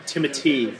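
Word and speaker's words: Timothée: but there was Timothy, I've been corrected, is how it's pronounced.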